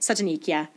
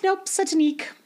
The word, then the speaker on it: Satanik: Is that how you pronounce that? Satanik, yeah. Nice. – Call I was me, hoping it wasn't satanic. But people call me satanic a lot because yeah. of how it's spelled. But yes, nope, Satanik.